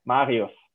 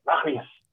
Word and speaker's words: Marius: And so, yeah, and I did an exchange here in the United States at a high school, and no one could pronounce my name, like the R sound in the middle, that Marius. Mm-hmm. They said like, Marius, Mario, Super Mario.